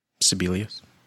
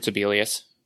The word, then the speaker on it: Sibelius: How do you pronounce his name then? – Sibelius. – I've always heard Sibelius. – Sibelius? Okay. So I wasn't far off.